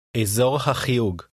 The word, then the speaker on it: אזור החיוג: Here's how to say the area code, literally area of the dialing. – הקשיבו ושננו. – אזור החיוג.